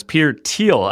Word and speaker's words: Thiel: Jay, I don't like to correct you on things, but it's Peter Thiel, and I thought you would know that considering his activity in politics.